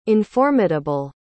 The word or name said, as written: informidable